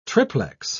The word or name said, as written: triplex